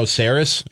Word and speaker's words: Sarris: You know, Sarris, am I saying that right? – Yeah, from the Athletic.